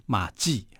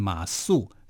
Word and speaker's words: 馬謖: 对对对，我们可以讲，这设计的“ 计” 是士字边嘛，啊，然后这个马謖的这个“ 謖” 它是言字边，两个人蛮像的，所以有时候会有边读边，无边念中间，是，那就会习惯把它念成说，哎，马记啊，但其实它念马謖。这个謖是没有卷舌的哈，吃素的素跟它是同音的。